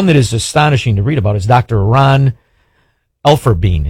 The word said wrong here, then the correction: Elfenbein: Is that how you say his name? Bin? How do you say his name? – Elfenbein. Yeah. – Dr. Ron Elfenbein, who was a respected physician charged by the Biden DOJ.